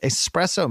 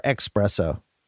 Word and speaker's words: espresso: By Keurig is like a premium espresso machine, but it makes cocktails instead. Jim, do you say espresso or expresso? – Espresso.